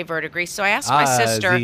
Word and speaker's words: Verdigris: He goes, verdigris.